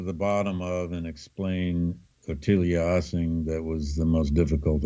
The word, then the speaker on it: Ottilia Assing: Perhaps the most curious is his relationship with, and I'm going to let you say her name because I'm not sure how to pronounce it. – It's Ottilia Assing.